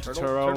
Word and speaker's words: Turturro: John Turtleman. I can't pronounce his last name. – John Turturro. – Turtleman? Yeah, Turturro.